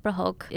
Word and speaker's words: prahok: Am I saying that right? – Oh yeah, prahok. Perfect.